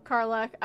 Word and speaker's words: Karlach: If that's how you say it. – Yeah, Karlach.